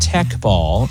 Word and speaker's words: Teqball: T-E-Q-B-A-L-L, pronounced Tech Ball.